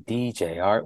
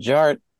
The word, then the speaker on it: DJarTT: DJ Art, with two T's for a double dose of that tink tink. The D is silent, so it's just Jart.